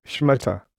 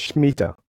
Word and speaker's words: Shmitah: It is spelled as S-H-M-I-T-A-H, but it is pronounced as Schmetter.